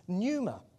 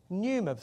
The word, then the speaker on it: pneuma: In the New Testament, there's another word, and the word for that is pneuma. Pneuma, spelt with a P, it's silent, but it's pneuma.